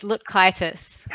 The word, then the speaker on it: Lutkajtis: It's Lutkajtis.